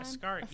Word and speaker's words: Ascari: Ascari. Did I say it right that time? – Ascari.